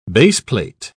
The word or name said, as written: baseplate